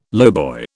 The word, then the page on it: lowboy: British English